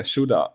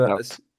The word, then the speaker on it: Shoutout: Shootout. Wie sagen wir das, wenn Shoutout.